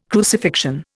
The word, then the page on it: crucifixion: Indian